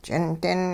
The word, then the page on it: ch'indin: hupa